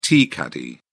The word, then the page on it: tea caddy: British English